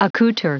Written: accouter

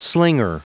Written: slinger

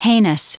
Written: heinous

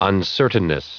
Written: uncertainness

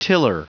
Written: tiller

